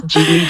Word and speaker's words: Ghibli: Con lo cual la pronunciación buena es Ghibli, pero yo como soy un poquito purista y en japonés es Ghibli, yo siempre digo Ghibli.